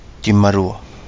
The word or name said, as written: DiMalua